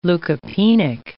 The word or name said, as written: leukopenic